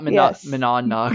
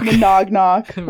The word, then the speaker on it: Monadnock: Monadnock, not Monadnock. – Monognock, which I said in my video and people called me out.